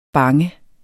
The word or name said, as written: bange